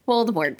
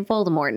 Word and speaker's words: Voldemort: I especially don't care that it's pronounced without the T now because if she said that so, I'm definitely going to say Voldemort now.